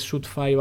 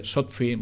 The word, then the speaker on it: Shootfy: Eh, Sotfi, mucha, la gente dice Sotfi, porque es verdad que es, eh, como se escribe, es S-H-O-O-T de Tarragona, F y Y.